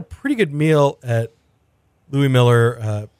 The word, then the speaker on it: Mueller: But Felice, you and I did take a little road trip this last weekend to Louis Miller Barbecue in Taylor, Texas. And I know I'm saying Miller, it's spelled like Mueller.